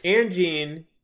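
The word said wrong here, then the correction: InGen: It's Engen. It's like Jurassic Park. Engen.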